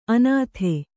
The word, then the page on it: ಅನಾಥ: anātha